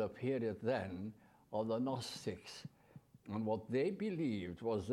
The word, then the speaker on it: Gnostics: Now that's spelled with a G, Gnostics. It's from the word that you can familiar with, agnostic.